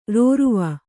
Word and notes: ರೌರವ: raurava

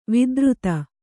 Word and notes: ವಿಧುರಿತ: vidhurita

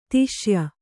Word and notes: ದೇಶೀಯ: dēśīya

